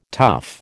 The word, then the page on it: tuff: American English